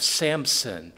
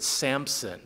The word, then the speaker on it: Samson: I was reminded tonight that for most of my life, Brother Garcia, I think I've pronounced it Samson, which is just wrong. Samson. There's no P in there. Samson. Not in this man's name, at least.